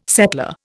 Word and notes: settler: Indian

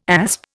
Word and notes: asp: Indian

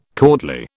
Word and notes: courtly: British English

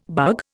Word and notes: bug: Indian

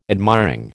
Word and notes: admiring: American English

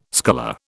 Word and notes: sculler: British English

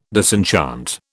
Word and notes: disenchant: British English

